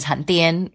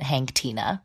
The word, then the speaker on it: Hanh Tien: Oh, the Hank Tina of it all is that my full name, Vietnamese name, is Han Tien, which is spelled H-A-N-H-T-I-E-N. And one time in high school, I had a substitute teacher who just, like, reading through fucking roll call, got to my name and said Hank Tina.